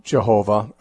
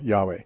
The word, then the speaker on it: Yahweh: I think most modern scholars agree that Yahweh is a much better, much closer approximation to the way it should be pronounced rather than Jehovah.